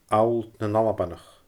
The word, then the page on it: Allt nan Albannach: Scottish Gaelic